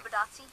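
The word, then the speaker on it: Bitadze: It was an interesting pick that they picked Bataze, or however you pronounce his name. – Bidazzi.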